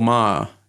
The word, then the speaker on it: Maher: The Milo interview with Bill Maher, is that how you say it? Yeah. I saw someone going back and forth about how it wasn't constructive to criticize it.